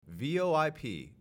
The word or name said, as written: VoIP